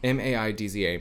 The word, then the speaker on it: Maidza: My, Maiza? – Oh, yes. Mm-hmm, How do you mm-hmm. say her name? I don't know. M A I D Z A. My bad, y'all. I'm Maidza.